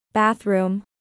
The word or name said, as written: Bathroom